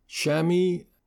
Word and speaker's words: Chamois: Of particular concern were two particular malware, Shamis, that's spelled Chamois, and Triada, Triada.